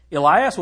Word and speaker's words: Elias: Elias was a man subject, that's the Greek pronunciation of Elijah.